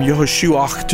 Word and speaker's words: Yehoshua: Now, this is the Hebrew pronunciation. The last part of it, that guttural sound, is that Hebrew letter Ein, as I understand. And over time, as the people began to speak more and more Aramaic instead of Hebrew, that pronunciation was shortened from Yehoshuach to just Yeshua.